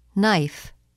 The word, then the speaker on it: knife: The letter K is not pronounced in the following words. Repeat them during the pauses. – No. New. Knowledge. knee, knife, blackguard.